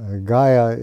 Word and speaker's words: Gaya: Then the next is Gaya. This is the right one, short A. Gaya is glorified in the 5th canto.